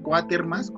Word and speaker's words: Quatermass: El, experimento del doctor Quatermass, Quatermass, pues, así, Quatermass, como suena con doble S. pronúncialo como inglés.